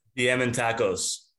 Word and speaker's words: Diamantakos: Diamantakos.